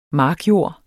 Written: markjord

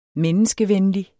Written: menneskevenlig

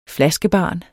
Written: flaskebarn

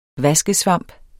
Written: vaskesvamp

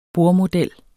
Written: bordmodel

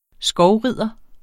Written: skovrider

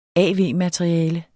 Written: av-materiale

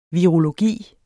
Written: virologi

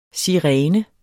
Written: sirene

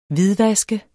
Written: HVIDVASKET

